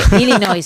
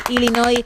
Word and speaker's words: Illinois: Illinois, sin la S. Así que, bravo, sin pronunciar la S final, Illinois, una vez más, tu apunte de geografía, que vale.